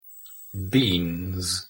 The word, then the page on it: beans: English